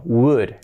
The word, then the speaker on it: would: Cuidado la pronunciación, que no es would ni would, no, would. Esta es una de las palabras más complicadas de pronunciar en inglés. Would, would, would. Es como could, pero sin la C y poniendo este wu, wu, wu. Would, would, would. La L fuera. Would, y este sonido, would, would, con todo abierto, que no sea good ni would, would, would, would, así sería, would.